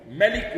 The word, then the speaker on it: ملك: فملك تقرأ بوجهيها المنزلين، ملك ومالك، ومثلها غيابتي وغياباتي ورسالتي ورسالات، واضح؟